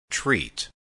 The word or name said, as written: treat